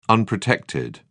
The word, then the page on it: unprotected: British English